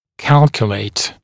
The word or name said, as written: calculate